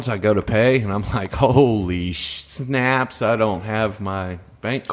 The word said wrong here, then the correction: schnapps: Snaps doesn't start with a sh. – Schnapps.